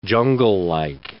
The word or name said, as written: junglelike